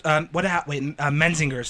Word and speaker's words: Menzingers: Memphis Mayfire, The Messengers, which is Menzingers. That's how you say it.